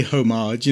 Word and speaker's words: homage: Like, you could tell me it's spelled O-M-A-G-E, but you say homage, you know.